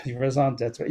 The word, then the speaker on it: raison d'être: d'être, raison Raison d'être.